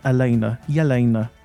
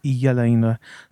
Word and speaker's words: Elena: And his film is called Yelena. It's spelled Elena, but it's said Yelena.